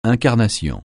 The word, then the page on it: incarnation: francais